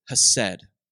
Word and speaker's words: chesed: The word there is chesed, and it's spelled C-H-E-S-E-D.